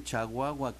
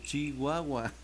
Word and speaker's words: Chihuahua: Me dio mucha risa que es, dígale que no se dice Chihuahua, que se dice Chihuahua.